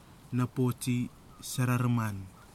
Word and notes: napoti sarariman: kwamera

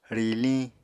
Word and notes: riliñ: Apatani

